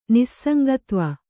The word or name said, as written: ನಿಸ್ಸಂಗತ್ವ